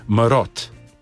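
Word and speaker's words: marod: Z D-jem na koncu, nekje s T-jem, tako ali tako je slišati T, ko človek izgovori. Marot.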